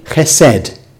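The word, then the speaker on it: chesed (חסד): Le mot en hébreu qui se prononce, pour intérêt, chesed.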